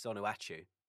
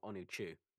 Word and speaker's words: Onuachu: Onuachu. I'm enjoying saying these names. I think you can tell this. – I mean, you're saying it incorrectly. It's Onuachu, not Onuachu.